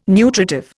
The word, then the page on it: nutritive: Indian